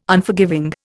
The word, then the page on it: Unforgiving: Indian